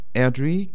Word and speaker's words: Airdrie: Alors Airdrie, Coatbridge et Greenock. C'est ça, je les prononce sans doute assez mal, mais je vais vous laisser le faire peut-être. – Non, non, c'est très bien prononcé.